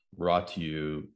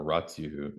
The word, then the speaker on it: Ratiu: Ioan Ratiu, or Ratiu is spelled R A T I U.